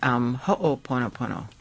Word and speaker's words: Ho'oponopono: What exactly is Ho'oponopono? Is it Ho'oponopono? – Yes. – What's the correct pronunciation? 到底怎么样正确发音? – Yes, Sally. Ho'oponopono. – Ho'oponopono. – So the ho'o is to cause, and then ponopono to make right. – Oh, okay. So ho'o is 造成，然后 ponopono 就是要把它变正确，做正确。 Okay. Ho'oponopono.